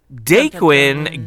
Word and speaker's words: Daquine: He goes by, is it Daquin or Daquin? How do you say? – Daquin Game Army.